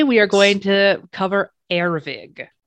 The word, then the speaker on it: Ervig: I'm pronouncing it Ervig.